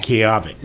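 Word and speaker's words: Kiawe: This is called, if I pronounce it right, Nakoa Moku o Kio. – Kiave. – Kiave. – There you okay? – go. You almost got it.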